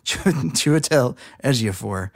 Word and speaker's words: Chiwetel Ejiofor: Chiwetel Ejiofor. – You had it right the first time. – Chiwetel Ejiofor.